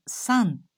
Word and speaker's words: 山: Although its pronunciation is the same, a mountain is Yama, but it is pronounced as san when attached to the names of some mountains.